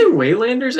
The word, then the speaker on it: Waylanders: We say Waylanders, I think.